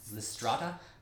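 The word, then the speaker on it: Lysistrata: It's like Listerasti. – Oh! Lystrata.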